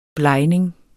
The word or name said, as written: blegning